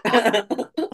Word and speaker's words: Phuket: You're like, it's pronounced what? Phuket. – Pronounced Phuket. No, definitely not.